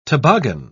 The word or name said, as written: toboggan